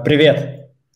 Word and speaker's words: Привет: Uh, privet, privet.